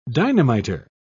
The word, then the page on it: dynamiter: British English